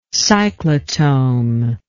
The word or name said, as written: cyclotome